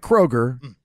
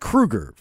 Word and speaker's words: Kroeger: We'll have Chad Kroeger, not Kroger, Chad Kroeger from Nickelback on. It's not a joke. That's actually how you properly pronounce Chad Kroeger's last name.